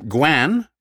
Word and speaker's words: guan: Other lingual tics of note included the term guan, which is uh, the contracted go on.